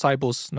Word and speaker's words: Cybus: Ähm, hattest, hattet ihr vielleicht noch irgendwie andere Herausforderungen jetzt auch in der Gründungsphase damals äh, von, von Cybers? Oder wie spricht man es aus? Cybus, ne? wie das Bus-System oder?